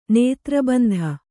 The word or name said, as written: ನೇತ್ರಬಂಧ